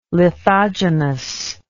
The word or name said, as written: lithogenous